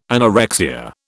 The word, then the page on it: anorexia: British English